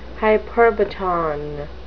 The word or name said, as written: hyperbaton